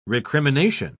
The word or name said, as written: recrimination